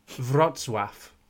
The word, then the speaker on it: Wrocław: So it's spelled WROC, L with a line through it, AW, and it's pronounced, let me listen again, Wrocław.